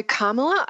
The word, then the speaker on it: Kamala: Yeah, so Kamala, I think, is the right pronunciation.